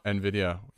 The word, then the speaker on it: Nvidia: Nvidia, did I say that right? – Nvidia.